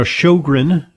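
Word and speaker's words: Sjögren: You know, Sjogren, it's really spelled in a weird way. It's S-J with a O with a little umlaut over it. And it's spelled the Swedish way because Sjogren was the guy in Sweden who first discovered it.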